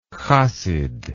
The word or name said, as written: chassidism